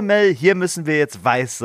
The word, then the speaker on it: Vyse: Die Agentin heißt Weiß. Das Ganze wird geschrieben. V-Y-S-E.